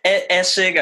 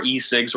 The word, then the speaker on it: Essig: Essig, I guess, like it's on the first syllable.